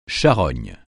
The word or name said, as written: charogne